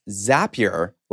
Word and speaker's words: Zapier: I think you mentioned Zapier earlier. Where does Zapier fit in or other iPaaS solutions? – And fun fact for you, I learned because they're one of our advertisers, it's pronounced Zapier, like happier.